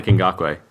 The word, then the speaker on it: Ngakoue: This might be a bit of an out there name to start off with at defensive end, but I've got Yannick Ngakoue. And a lot of talk about Dante Fowler, kind of early first round pick. But Yannick Ngakoue... – I'm impressed you pronounced that correctly.